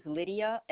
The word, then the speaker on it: Lydia: First name is Lydia, L-Y-D-I-A.